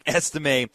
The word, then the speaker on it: Estime: When I see a name with a little asterisk over the E, little French lesson for everybody that has an A sound at the end. So Audric Estime has been averaging six yards per carry over the past two games 35 times, 210 yards, three touchdowns.